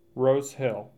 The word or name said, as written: Rose Hill